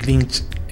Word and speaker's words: Lynch: Y me daba una explicación acerca de un director de cine, David Lynch, lo pronuncié bien. – Sí, Lynch.